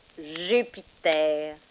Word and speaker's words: Jupiter: Jupiter.